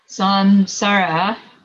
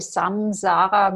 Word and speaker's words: samsara: Geshe-la made the point here that samsara, sam with an M, sara is a mispronunciation. – Und Geshe-la hat jetzt nochmal aufgezeigt, dass die Aussprache samsara mit M falsch ist.